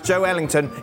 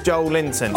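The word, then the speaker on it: Joelinton: It's our Brazilian researcher, and he wrote, Joel Linton is pronounced Joe Ellington. It's not pronounced Joel Linton.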